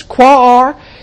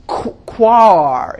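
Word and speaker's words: Quaoar: I think it's Quaar is how you pronounce it.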